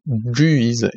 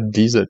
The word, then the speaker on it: Guise: doit en toute rigueur être prononcée Guise et non pas Guise comme on l'entend bien trop souvent.